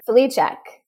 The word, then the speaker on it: Flicek: Flicek.